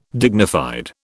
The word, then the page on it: dignified: British English